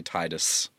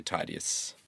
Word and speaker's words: Tydeus: Some people say Titus, but I say Tydeus.